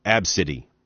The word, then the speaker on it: Abcde: Her name is A, B, C, D, E. That's how you spell it. It's pronounced, though, Absidy.